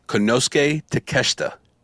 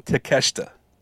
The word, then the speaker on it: Konosuke Takeshita: say his name one more time. – Konosuke Takeshita. – Konosuke Takeshita. Takeshita. – Konosuke Takeshita. – Takeshita. – There you go.